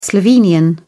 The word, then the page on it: slovenian: British English